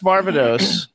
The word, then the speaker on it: Varvatos: How do you pronounce Varvatos? John Varvatos. What's the correct pronunciation? – That's exactly, you just did it.